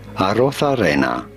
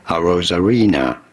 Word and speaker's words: Arozarena: In Spanish, his name would be said as Arozarena. But in English, or in America, it is generally said as Randy Arozarena. Randy Arozarena. – Yeah, that's how Arozarena.